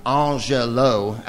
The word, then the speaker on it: Angelou: In any case, Dr. Angelou, Angelou, as she pronounced her name.